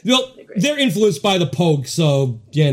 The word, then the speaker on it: Pogues: It's, nope. No, it's like, P-O-U and P-O-G-U-E-S. Pogues. – Pogues. – Pogues. – Like Vogue, but like Pogues.